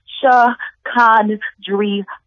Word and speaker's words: Chicondria: It's pronounced Chicondria, no silent letters, no accents, preferably pronounced with the drawl of a southern accent.